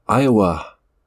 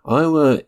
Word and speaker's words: Iiwaa: That was Iowa. I think that's how you pronounce it. It's spelled I-I-W-A-A. Iowa is a non-binary recording artist and poet, and Quechan and Pipash, and an enrolled member of the Gila River Indian community.